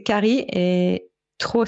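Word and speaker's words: Kari Traa: Alors, c'est Cari et Trau, c'est T-R-A-A.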